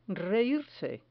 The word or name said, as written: reirse